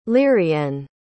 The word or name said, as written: lirienne